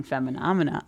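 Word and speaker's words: Femininomenon: Well, I did successfully play Chapel Roan Feminomena. Now I can't speak. I can't even play a song, let alone pronounce it. – Phenomenon with fem.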